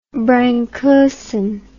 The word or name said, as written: brank ursine